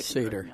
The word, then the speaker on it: Seder: We did a Seder, I think it's spelled S-E-D-A-R. – Seder, yeah.